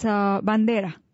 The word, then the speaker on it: bandera: word in Spanish. It's bandera. – Bandera. – Bandera. – Okay. – Like Antonio Banderas.